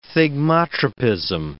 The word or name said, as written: thigmotropism